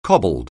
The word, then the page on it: cobbled: anglais